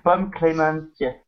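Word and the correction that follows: Pom Klementieff: Ouais, Pomme Clémentieff.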